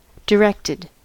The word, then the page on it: directed: American English